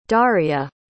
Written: daria

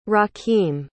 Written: Rakeem

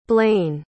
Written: blayne

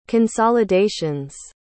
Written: consolidations

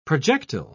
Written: projectile

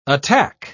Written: attack

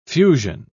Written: fusion

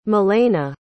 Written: milena

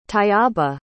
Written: tayyaba

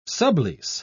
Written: sublease

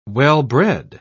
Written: well-bred

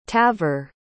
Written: tavor

